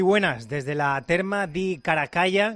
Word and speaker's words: Caracalla: Que tienes que decir Una sílaba acaba en L y la siguiente sílaba empieza por L. – Los idiomas no son los suyos. – Es bastante sencillo. Caracalla. – Caracalla.